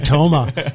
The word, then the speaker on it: Toma: And you pronounced his name correctly. I was just going to say Toma. You put that emphasis on it.